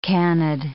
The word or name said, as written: canid